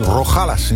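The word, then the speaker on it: Rojales: Zo schrijf je het. Maar dan hebben we hier weer de J. – Ja. – En die spreek je uit als een G. Dus dan is het, we zitten nog in Rogalas in Spanje.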